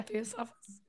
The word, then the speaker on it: Theosophists: The oh my gosh, how do you say this? Theosophists? – Yeah. – Theosophists. Okay. – You got it.